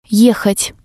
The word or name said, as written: ehat